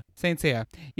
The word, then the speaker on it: Saint Seiya: The Saint Seiya, is that how you say it? Saint Seiya? How do you say it? – Yeah. Saint Seiya. – Saint Seiya.